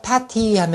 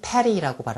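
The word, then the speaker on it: petty: 자, 패티 하면은 사소한, 보잘것없는 또 패티 또는 p 리라고 발음합니다. p 리 또는 패티.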